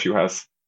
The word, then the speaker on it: Juhász: It's Andras Juhas.